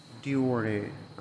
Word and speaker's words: Deore: It's not spelled like the sunglasses, Dior, like D-I-O-R. No, it's not spelled that way. It's spelled D-E-O-R-E. Diore, I guess.